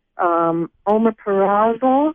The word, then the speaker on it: omeprazole: Thank you, Dr. Don, and I would like to know what you think about BGL licorice for a peptic ulcer versus omeprazole Is that how you say it? – Yes, and thank you.